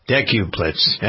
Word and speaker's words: decuplets: Decuplets? How do you pronounce it? Decuplets? Deck is 10. Decuplets.